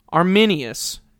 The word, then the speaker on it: Arminius: But listen, Jacobus, that's how you say it, Arminius.